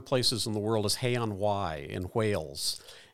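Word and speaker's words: Hay-on-Wye: Hay on Wye.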